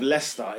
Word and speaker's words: Leicester: Why is there an I in Leicester? So you don't even pronounce it. – It should be L-E-S-T-R.